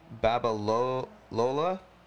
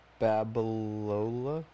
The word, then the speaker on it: Babalola: Babalola. That's that's. I, am hoping I'm saying his name correctly, or Babaloa, Lola. B a b a l o l a. Lola is the end. Okay. Babalola. Babalolo.